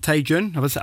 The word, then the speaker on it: TaeJoon: We've got TaeJoon.